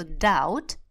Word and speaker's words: doubt: А мы теперь с вами до сегодняшнего момента мучились, но мучиться больше не будем, просто запомним, что doubt Doubt. Помните, у Гвен Стефани была такая группа No Doubt, без сомнений. Doubt, буковка B вообще не произносится.